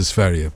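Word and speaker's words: Sverige: No, Sveria is the right correct pronunciation. Well done, you, Ruby. Yeah, it's Sveria.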